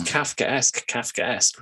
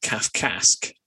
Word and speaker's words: Kafkaesque: We still pronounce Kafkaesque. We don't call it Kafkask, do we? – No, because that's how you pronounce a vowel diphthong in English.